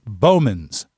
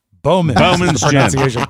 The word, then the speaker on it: Bowman's: I just checked the way you pronounce that gin is Bowman's. – Bowman's gin. – Bowman's.